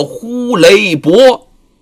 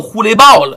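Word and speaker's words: 呼雷驳: 咱还得强调一遍，上师徒骑的不是马，是个象马的怪兽，叫做伯，应该叫呼雷伯，只不过大家呢把这音儿给念转了，念成了呼雷豹了。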